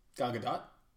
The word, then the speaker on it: Gadot: You know, you pronounce the T. – Gal Gadot.